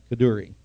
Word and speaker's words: Kaduri: Now, there's a rabbi by the name of Yitzhak Kaduri, I remember how to pronounce it now, Kaduri.